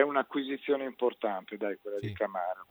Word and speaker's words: Camara: Tra l'altro, per chiudere, mi fa piacere che tu abbia detto Camara e non Camara, come dicono tutti, perché la pronuncia corretta è Camara, sarebbe Camara, come tutti i giocatori africani che noi ci ostiniamo ad accentare per l'influenza francese, ma se lo vai a chiedere a loro non c'è una neanche Ture.